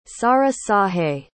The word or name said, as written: sahrahsahe